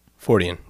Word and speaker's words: Fortean: Fortean. Yep. It's pronounced.